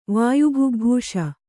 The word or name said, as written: ವಾಯುಭುಗ್ಭೂಷ